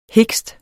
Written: hikst

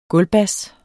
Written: gulvbas

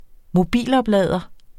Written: mobiloplader

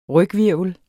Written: ryghvirvel

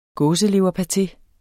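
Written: gåseleverpate

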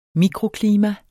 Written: mikroklima

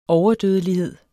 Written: overdødelighed

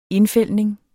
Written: indfældning

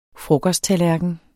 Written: frokosttallerken

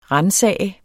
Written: ransage

